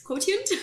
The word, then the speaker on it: quotient: Quotient? How do you say it? – It's emotional quotient. – Quotient, yes.